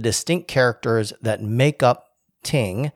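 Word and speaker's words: ting (聽): So in traditional Chinese, the verb to listen is pronounced ting, and that literally in traditional Chinese means to listen.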